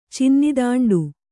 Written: ಚಿನ್ನಿದಾಂಡು

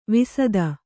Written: ವಿಸದ

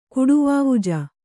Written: ಕುಡುವಾವುಜ

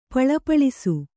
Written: ಪೆಳ್ಪಳಿಸು

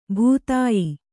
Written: ಭಿದ್ಯ

